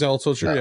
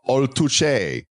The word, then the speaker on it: Altucher: Altucher, right? – Close. Yeah, James Altucher, yeah. – Altucher. It's Italian. Altucher.